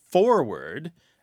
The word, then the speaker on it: forward: Now, there are people, by the way, who pronounce forward to rhyme with Ford, F-O-R-D. And as a matter of fact, there are people who say the word forehead and the word forward, they rhyme. They sound like homophones.